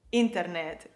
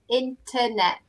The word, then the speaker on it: internet: Internet. Is that how you pronounce it? – The effort needs to be on the first T. Internet.